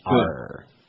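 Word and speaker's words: Paar: Jack Parr. P-A, two A's. P-A-A-R. Got it? – Parr. – What? – Oh, rhymes with Parr.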